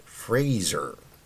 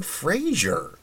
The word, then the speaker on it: Fraser: But yeah, I mean, we've all been saying Fraser and not Fraser.